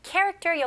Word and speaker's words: character: 자, character 여기 ch, k 발음이죠? character, character. Character 강세가 어디 들어가요? 네, 첫 번째 음절이죠.